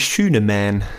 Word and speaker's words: Schünemann: Also wenn ich den amerikanisch ausspreche, heißt der Corey Schünemann. – Schünemann. – Er könnte Schünemann heißen. Er wird aber nur mit einem N am Ende geschrieben. – Schünemann. Ist jetzt nicht wie Mauermann.